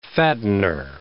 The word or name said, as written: fattener